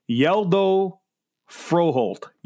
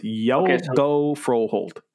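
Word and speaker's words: Hjalte Froholdt: Here's how you say it. I have the pronunciation. It's Yeldo Froholt. Yeldo Froholt. That's from Denmark. That's how you pronounce it. Yeldo Froholt.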